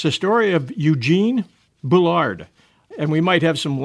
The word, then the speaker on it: Bullard: But because he spent most of his lifetime in France and because his father came from Martinique as a slave, it's likely pronounced Boulard. Hmm.